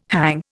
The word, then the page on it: hang: Indian